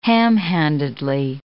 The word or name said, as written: ham-handedly